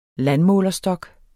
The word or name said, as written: landmålerstok